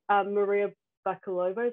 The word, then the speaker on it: Maria Bakalova: And then Maria Bakalova, is that how you say it, from Borat 2? – Yeah.